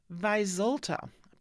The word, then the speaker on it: Vyzulta: Vyzulta, better spell that, V-Y-Z-U-L-T-A.